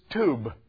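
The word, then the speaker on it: tube (טוב): The other Hebrew word is tube. Pronounce this with me.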